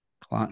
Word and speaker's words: Klim: Klim. – What the hell? How do you spell it? Rhyme with slime. – Klim. – It's Klim. – K-L-I-M.